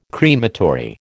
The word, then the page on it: crematory: American English